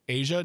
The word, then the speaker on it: Aja: yes, I have been pronouncing it incorrectly. Two reasons. One, because of the WNBA player. And two, one of my bestest friends, her name is also AJA, Asia, named after the Steely Dan album.